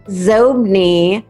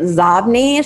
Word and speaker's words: Xobni: Yeah, X-O-B-N-I. It was originally pronounced Zobni, but then Bill Gates pronounced it Zobni.